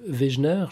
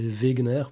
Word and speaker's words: Wegener: Donc c'est, enfin aujourd'hui elle est amplement vérifiée et acceptée de tous, mais la théorie de la dérive des continents d'Alfred Wegener, je crois que c'est comme ça qu'on le prononce en français, c'était un allemand, hein, ça serait Wegener plutôt en version originale.